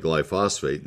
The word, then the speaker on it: glyphosate: Back about 45 years ago, Roundup was introduced into the market, and it was the only active ingredient was glyphosate, G-L-Y-P-H-O-S-A-T-E. It looks like glyphosate, and then you realize, oh, there's a syllable that's not in there. It's glyphosate.